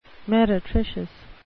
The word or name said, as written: meretricious